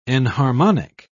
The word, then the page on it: enharmonic: British English